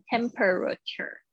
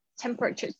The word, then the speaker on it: temperature: 大家注意下，注意一下你们 temperature，不要念成 temperature，这个这个 e 在这边很轻的 temper，temperature，temperature。 temperature 甚至一听不到了。